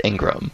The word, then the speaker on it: Ingraham: I thought it was Ingram. – The Ingram. I should probably figure that out before Friday night, huh? – It's spelled Ingraham, but I believe it's pronounced Ingram. Laura Ingram.